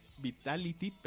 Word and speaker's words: Vitality: Pero bueno, vamos a continuar con que Vitali, Vitality, perdón, se queda sin Hachani.